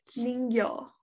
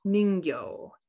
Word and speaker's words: Ningyo: So we're going to be talking about the Ningyo, I think is how you say it. – Ningyo. – Ningyo. N-I-N-G-Y-O. – Ningyo. – Ningyo. They are described as human fish with the body of a fish, but the grotesque human head and face with fish teeth.